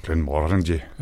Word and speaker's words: Glenmorangie: Øhm, og så blev det købt op af Glenmorangie, eller Glenmorangie, eller hvordan man udtaler det. – Glenmorangie. – Ja. Sådan kan man så også sige.